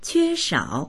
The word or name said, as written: que1shao3